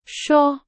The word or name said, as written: 刷